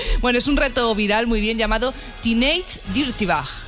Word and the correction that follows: Teenage Dirtbag: Teenage Dirtbag.